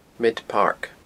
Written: Midpark

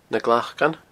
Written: Na Glacan

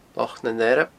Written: Loch nan Earb

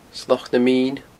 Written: Sloc na Mine

